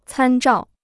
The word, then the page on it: 参照: cān zhào